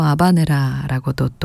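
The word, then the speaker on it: habanera: 원어 발음에는 또 아바네라라고도 또 저희가 소개해드리곤 하는데요.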